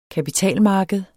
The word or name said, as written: kapitalmarked